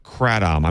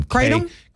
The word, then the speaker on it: kratom: One was called the Caritom Ban, Act 41, a complete ban on the possession, sale, and distribution of kratom. I may be saying that wrong. – Kratom? – Kratom. – Okay. – K-R-A-T-O-M.